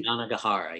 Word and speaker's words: Yanagihara: And like her previous book, I always mispronounce her last name. It's Yanagihara, right? – Yanagihara, yes.